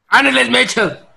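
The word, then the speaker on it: Anneliese Michel: En 1968, esta niña alemana, Annelies Michael o Annelies Michel, no sé cómo se pronuncia, cómo lo pronuncian. – ¡Annelies Michel!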